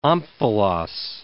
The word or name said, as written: omphalos